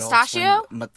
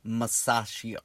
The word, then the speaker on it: Masaccio: So I'm going to talk about the mysterious life and death of the artist called Masaccio, or known as Masaccio. Because he wasn't born as Masaccio, but Mustachio? – It sort of sounds like mustachio, but it's not that. It's Masaccio.